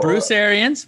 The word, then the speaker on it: Arians: Well, the only person that I do know is, I might pronounce his last name incorrectly. Is it Bruce Arians? Is that how? – Bruce Arians. Very good. Ding, ding, ding.